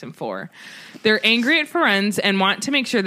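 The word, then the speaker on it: Firenze: We meet a new centaur named Ferenz. – That's what I call him, because okay. That's how they say it. – Jim Dale says Ferenzi.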